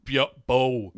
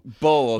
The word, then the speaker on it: Bo: Spelled weird, but I think it's pronounced Bo. – Bo, okay. – Like Bo Phillips.